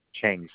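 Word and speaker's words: Cheng: J-I-H hyphen F like Frank, E-I. – Thank you. And that last name? – Chang, C-H-E-N like Nancy G.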